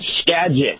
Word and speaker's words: Skagit: So we've invited who is from, is it, do you pronounce it Skagit Valley? How do you pronounce that? – Skagit, yep. – Skagit. – It's an Indian name, so that's a G, yep, like magic.